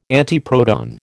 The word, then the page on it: antiproton: American English